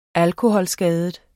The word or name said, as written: alkoholskadet